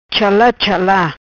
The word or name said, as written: Chala Chala